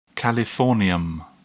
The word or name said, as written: californium